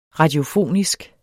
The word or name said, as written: radiofonisk